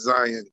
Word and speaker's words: Zyon: But for that very reason, like I didn't name my son Zion, like Z-I-O-N, because everybody kid his name is Zion. My son's name is Z-Y-O-N, and I say it that way. We pronounce it as Zion.